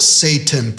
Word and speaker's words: Satan: The word Satan, pronounced Satan, is a Hebrew gerund, a noun form of a verb, meaning to entice or to be adversarial.